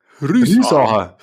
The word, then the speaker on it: Riesacher: Riesacher. Riesacher. Betonung auf der ersten Silbe und dann schön ausklingen lassen.